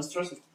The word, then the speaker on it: stressed: No stressed. – You can pronounce it better. – Okay. I feel tired, but not stressed. Stressed. – The final part. – Stressed. – Yes. – Stressed. – Yes, that's correct.